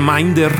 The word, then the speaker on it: MNDR: reclutar a Minder, si así es como debemos pronunciarlo, tal cual se escribe MNDR.